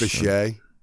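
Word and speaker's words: Bishé: So, Carrie Bish is his wife, Kathy. I hope I'm pronouncing her name right. – I think it's Bichet. – Bichet, okay. The hyphen over the E, that makes sense.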